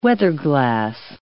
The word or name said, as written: weatherglass